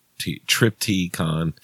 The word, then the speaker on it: Triptykon: It's spelled weird, though. It's like T-R-I-P-T-Y-K-O-N. Triptychon.